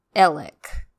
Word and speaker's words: Alex: Also, Alex is pronounced Ellick. That's just the way that it is. That's how all of my sources and people that know him say it.